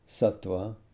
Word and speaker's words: sattva: The gunas are all pronounced with short vowels, so it's sattva, tamas, rajas. But when they become adjectives, that first vowel is elongated, so then it's sattvic, rajasic, tamasic.